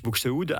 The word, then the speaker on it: Buxtehude: Quelques mots sur le choix de cet Alléluia de Buxtehude. Oui. Est-ce que je prononce bien ? – Je pense. En tout cas, l'ai compris. Oui, c'est l'Alléluia de Dietrich Buxtehude.